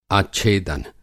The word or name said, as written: आच्छेदन